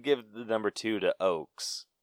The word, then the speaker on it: Oakes: I'm pretty sure that's how you say it. O-A-K-E-S? Oakes? – Yep.